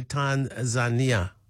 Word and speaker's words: Tanzania: Yeah, well, the locals say Tanzania. So, and that's about the way you said it, actually. Tanzania. So there's a bit of an emphasis on the knee.